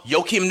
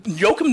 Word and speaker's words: Joakim: Yeah, I Joakim Noah, Jake. Joakim Noah. – Joakim Noah? – Yeah, well, I like the silent J.